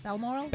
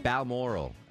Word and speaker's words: Balmoral: Passed away this afternoon at, uh, how do you pronounce that in, in, Scotland? Where was she at? Balmoral. Balmoral at the age of 96.